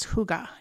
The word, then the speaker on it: hygge: That word is hygge, H-Y-G-G-E, I think. – That's not how I was going to pronounce it. I was going to pronounce it closer to hugs.